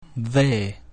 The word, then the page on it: V: Latin